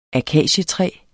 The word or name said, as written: akacietræ